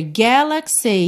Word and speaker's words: galaxy: Or we will run away to another galaxy, you know? Aqui, a terceira sílaba de galaxy ficou levemente, assim, mais forte do que normalmente ela é, de fato, porque a sílaba tônica é a primeira, né?